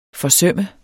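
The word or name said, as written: forsømme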